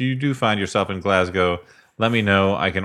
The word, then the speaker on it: Glasgow: Thanks must go to Elliott, who managed to pronounce Glasgow properly and not as Glass Cow, which many Americans do, to the endless frustration of Glaswegians.